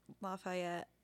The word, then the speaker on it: Lafayette: Norbert Randall, his wife, three children, and nephew were all murdered in Lafayette. I don't know if that's how you say it. – Yeah, that's how I've heard it.